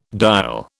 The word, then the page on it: diol: British English